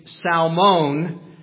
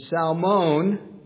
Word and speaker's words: Salmon: She marries a guy named Salmon, although in English it looks like the fish salmon, which you don't pronounce the L, that's confusing. But salmon, it's okay if you say salmon.